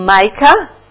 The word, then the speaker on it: Micah: So we have one reference, Bible reference for that in Micah. Is it Micah pronunciation? Yeah. Micah chapter 6, verse 8.